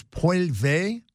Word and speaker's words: Poilievre: I think the way you pronounce his name is Poilievre, and criticizing Justin Trudeau and his political allies. Pierre Poilievre, I think is the way you pronounce it.